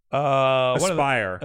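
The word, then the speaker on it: Aspyr: This is Asper. – Yes. Aspire. – Aspire. I'm so sorry. – I think that's how it's pronounced.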